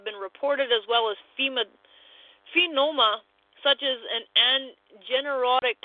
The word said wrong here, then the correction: angioneurotic: edema. I don't even know if I pronounced that right. Angioneurotic, there we go, edema, including peripheral and facial edema, bronchial spasms in individuals without an allergic history.